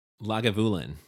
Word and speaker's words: Lagavulin: Lagavulin.